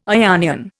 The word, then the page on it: Ionian: Indian